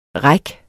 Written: reck

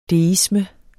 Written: deisme

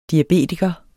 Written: diabetiker

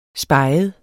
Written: speget

